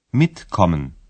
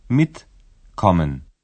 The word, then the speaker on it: Mitkommen: В інфінітиві префікс і дієслово не розділяються, і наголос падає на перший склад. – Mitkommen. Mitkommen.